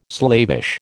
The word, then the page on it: slavish: American English